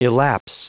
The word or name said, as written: elapse